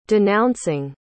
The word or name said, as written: denouncing